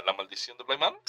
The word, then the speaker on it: Bly Manor: No se dice Bly Manor, se dice Bly Manor. – Ok, Bly Manor.